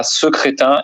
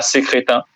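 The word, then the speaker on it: Secretin: Et de Jacques Secrétin d'ailleurs, qui est triste, c'est triste pour lui, c'est qu'on prononce ça Secrétin et pas Sécrétin.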